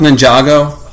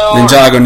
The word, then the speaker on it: Ninjago: They have, like, the Lego, is it pronounced Ninjago? – I think so. – Ninjago, Ninjago, whatever.